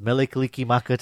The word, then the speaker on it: Mele Kalikimaka: And I just wanted to say, Malakalikimaka. I probably butchered that. Okay, thanks. Bye. – Thanks for your call, Christine. And yes, mele kalikimaka to you.